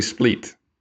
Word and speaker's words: Split: I didn't say it like that earlier because I was speaking in English, but in Croatian, Split.